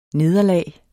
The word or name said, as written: nederlag